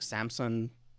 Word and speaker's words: Samson: But it's not the usual Sam. I think it's like Samson, you know, from the Bible.